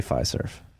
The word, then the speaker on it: Fiserv: How do you say Fiserv? – Yeah, Fiserv.